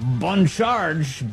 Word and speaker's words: Bon Charge: This half hour, we thank the fine people at Bon Charge. Really nailing that slightly French pronunciation.